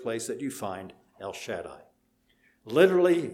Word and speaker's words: Shaddai: Shaddai, if you want to pronounce it in Hebrew, but it is where we speak English.